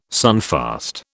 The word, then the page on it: sunfast: British English